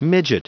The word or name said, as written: midget